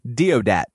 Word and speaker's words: Deodat: It's D-E-O-D-A-T. – Deodat.